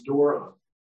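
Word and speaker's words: doron (δῶρον): It's it's doron. Rhymes with moron.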